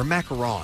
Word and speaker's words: macarons: And we have lots of friends here providing some goodies to eat, including Mixie Chicks macaroons and French macaroons or macarons. – Macarons. Yeah, I had to learn how to say it myself.